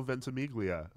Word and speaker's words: Ventimiglia: Milo Ventimiglia.